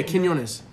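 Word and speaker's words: Quiñones: It's like Quinones or something like that. Q-U-I-N-O-N-E-S. – Oh, yeah. Quinones. Quinones.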